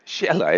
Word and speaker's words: Xela: There's not a single S in that word, Xela. It's X-E-L-A. How about this one? M-U-X-B-A-L, pronounced Mujbal.